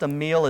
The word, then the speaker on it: Amiel: I believe his name is pronounced Amiel. It's A-M-I-E-L.